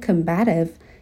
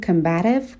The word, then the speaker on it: combative: هم میتونیم بگیم combative که بیشتر توی تلفظ آمریکایی به این شکل گفته میشه، صدای شوا در ابتدای کلمه هستش، استرس روی بخش دوم کلمه هست. هم میتونیم استرس رو بیاریم بخش اول کلمه که بیشتر توی انگلیسی بریتانیایی به این شکل تلفظ میشه و بگیم combative و دیگه در ابتدای کلمه صدای شوا رو نداریم.